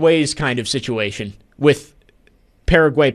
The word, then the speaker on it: Paraguay: And he says, He says it's Paraguay. He says his fiance is from there and had a come apart hearing me say that the other day. So I just looked up multiple videos, and some of them do say Paraguay, but then there's a video of locals saying it and they say Paraguay.